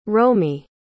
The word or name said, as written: romy